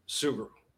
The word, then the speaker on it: Sugrue: And am I pronouncing your last name correctly? – Sugrue. – Sugrue. Okay, perfect.